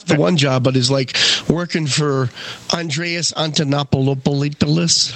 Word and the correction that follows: Antonopoulos: How do you say his name? I don't know. What's his name? – Something Greek. – Something close. – My Greek. Antonopoulos.